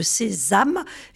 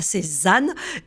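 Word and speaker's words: Sézame: Je rappelle que vous êtes cofondateur de Sésame et non pas Sézanne. Et Sésame, ça s'écrit S-E-Z-A-M-E.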